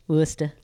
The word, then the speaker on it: Worcester: She'll come down Worcester. – Worcester.